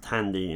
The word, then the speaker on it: Thandi: And Thandi is pronounced Tandy in English, it says.